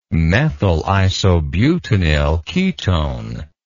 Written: methyl isobutenyl ketone

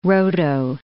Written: roto

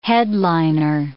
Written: headliner

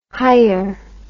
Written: hier

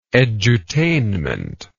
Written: edutainment

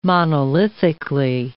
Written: monolithically

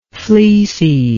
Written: fleeciness

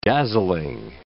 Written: dazzling

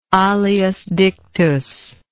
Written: alias dictus

